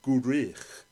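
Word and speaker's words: Gwrych: And for those of you who watch I'm a Celebrity, Get Me Out of Here, Gurich is how you say it. It sounds a little bit Klingon, but Gurich.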